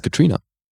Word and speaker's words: Catriona: Die heißt Katrina. – Ach, Katrina wird es ausgesprochen. – Ja. – Catriona, Katrina. – Das ist halt wie bei Saoirse. Das ist das O ist einfach quasi stumm.